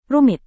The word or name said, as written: rumit